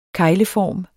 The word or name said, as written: kegleform